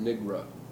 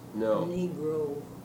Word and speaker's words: Negro: the pronunciation nigra, was that common? – No. Negro. – Negro, that's sort of a northern pronunciation, isn't it? – Yes, it is, but you can't, if you're going to say nigra, you're going to run into nigger.